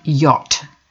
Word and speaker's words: yacht: Note, pronunciation of yacht. It rhymes with what.